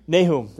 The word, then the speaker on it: Nahum: How do you say this name? Nahum, that's right. That's the English pronounced.